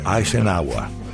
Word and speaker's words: Eisenhower: Se pronuncia Eisenhower. Eisenhower. – Como iba diciendo, Eisenhower. – Hay sin agua.